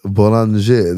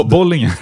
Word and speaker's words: Bollinger: Boulanger, det är så man uttalar det egentligen. Jag tror jag inte sa det så. Nej, ja, så, men den bo, Bollinger? – Bo, Bollinger. Boulanger.